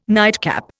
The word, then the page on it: nightcap: Indian